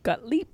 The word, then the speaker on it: Gottlieb: So between the years of 1953 and 1973, at the lead of Dr. Sidney Gottlieb, I think that's how to pronounce his name. – Gottlieb. – G-O-T-T-L-I-E-B. – Gottlieb. – I've also seen it spelled G-O-T-L-I-E-B.